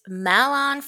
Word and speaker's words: Malon: Malin. That is a very interesting way to pronounce her name. You got it. Double points because you got the game. It is Malon from Ocarina of Time.